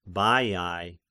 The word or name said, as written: Baiai